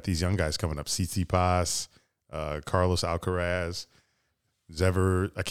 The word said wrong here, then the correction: Zverev: Zverev. Zverev.